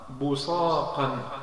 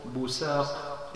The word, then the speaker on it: بصاق: يجوز في اللغة أن تنطق بالصاد، يجوز أن تقول بصاق بالصاد، ويجوز أن تقول بساق بالسين، ويجوز أن تقول بزاق بالزاد، فكل هذه اللغات يعني صحيحة في هذه الكلمة.